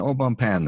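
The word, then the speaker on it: Au Bon Pain: There's an au bon pan there at LaGuardia. That's the French pronunciation.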